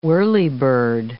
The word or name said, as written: whirlybird